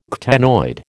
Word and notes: ctenoid: American English

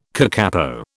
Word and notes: kakapo: British English

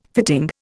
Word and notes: fitting: Indian

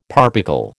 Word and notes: parbuckle: American English

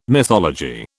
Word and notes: mythology: British English

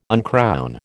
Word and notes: uncrown: American English

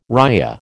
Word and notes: ria: American English

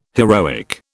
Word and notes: heroic: British English

